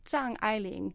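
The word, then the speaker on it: Zhang Ailing (张爱玲): She is so famous now that if you can pronounce her name as Zhang Ailing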